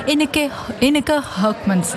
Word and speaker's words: Ineke Hurkmans: Ineke Hurkmans.